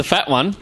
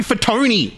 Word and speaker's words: Fatone: Was it Joey the Fat One? – Yeah, Joey Fatone. – Yeah, Fat One. – No. Just because it's spelt that way isn't the way it's pronounced.